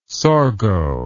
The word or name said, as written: sorgho